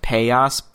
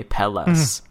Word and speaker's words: Pelas: Okay, so I got I got confirmation that technically it's a Spanish term that's pronounced Payas, but they call it Pelas. – Okay, like that's what everybody calls it. So they said it's okay to say Pelas.